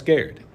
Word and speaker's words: Garrard: R R A R D which looks like Gerard, um, but it's pronounced Garrett.